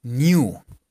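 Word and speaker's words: knew: K-N-E-W. K-N-E-W. Y se pronunciaría new, como si fuera una I.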